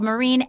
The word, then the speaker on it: Myrin: My last name is spelled Myrin, M-Y-R-I-N. Anna Hossnieh, H-O-S-S-N-I-E-H, and do hashtag W-Y-A-T-R.